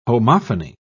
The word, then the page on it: homophony: British English